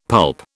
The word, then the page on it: pulp: British English